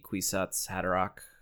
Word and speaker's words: Kwisatz Haderach: I went with the hard C, Kwisatz Haderach.